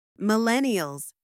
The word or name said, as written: Millennials